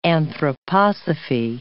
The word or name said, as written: anthroposophy